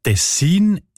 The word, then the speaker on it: Tessin: Ja, tatsächlich variiert die Betonung von dem Kantonsnamen, der sich übrigens vom gleichnamigen Fluss ableitet, von Region zu Region oder sogar von Person zu Person. Die Variante Tessin ist häufiger.